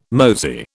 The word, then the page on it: mosey: British English